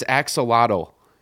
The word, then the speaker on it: axolotl: I think it's axolotl. – Axolotl, okay. – You know, there's a Y in there that's a bit weird, so okay.